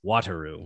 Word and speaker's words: Wataru: And it's like, okay, you got to pronounce it like it's spelled like W-A-T-A-R-U. And I keep wanting to say Wataru. And he's like, no, it's Wataru. I'm like, okay, sorry, Wataru. and in that case, specifically, my technique for me was just think Waterloo and you'll pronounce it right. So Waterloo, Wataru.